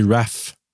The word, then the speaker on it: Ralph: Oui, ça s'écrit Ralph, mais ça se dit Raph.